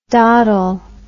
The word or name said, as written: doddle